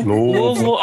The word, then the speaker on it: Louisville: No, Louisville only. – Louisville. – No, only. No, we don't say any other way. If you say any other way, you're saying it wrong.